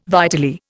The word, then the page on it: vitally: Indian